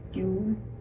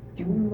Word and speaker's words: Juul: Margaret June Holmes. June was my maiden name. – And how did you spell that? – J-U-U-L. – J-U-U-L.